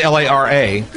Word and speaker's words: Lara: Yes, this is Lara. – Oh. – Like Lara's theme from Dr. Zhivago.